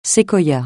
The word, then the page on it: séquoia: francais